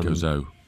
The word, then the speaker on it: Gozo: Um, Steve is in Goza. Um, Gozo. – I keep saying it wrong, don't I? Gozo. Gozo.